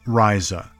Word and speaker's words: Reise: Her name is pronounced Reise, and is German for journey.